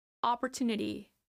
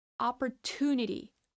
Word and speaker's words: opportunity: The stress is in the third syllable in the t. Opportunity. Opportunity. Opportunity. So we start with an ah, then we move on to the per, per, per. You have a schwa sound upper, upper. Then we have the stressed syllable, upper two, upper two. And then we end with nitty, nitty.